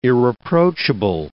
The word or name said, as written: irreproachable